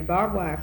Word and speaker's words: barbed: And there are barbed wire fences, which is spelled B-A-R-B-E-D, but you don't pronounce the E-D on the barbed wire fence.